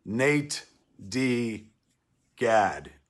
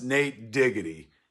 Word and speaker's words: natedigad: It's pronounced Nate Diggity in your ears.